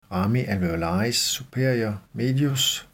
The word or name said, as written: ramus alveolaris superior medius